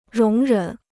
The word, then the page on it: 容忍: róng rěn